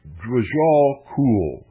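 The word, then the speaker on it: Djwhal Khul: She wrote over 20 books with the help of her, quote, spirit guide, end quote, the Tibetan master Drajal Kul. I'm not sure I pronounced the first word correctly, so I will spell it for you. Capital D-W-J-H-A-L. The last name, capital K-H-U-L.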